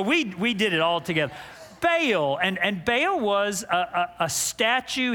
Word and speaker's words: Baal: And Israel at that time has been overrun by the worship of an idol, a false god named Baal, B-A-A-L.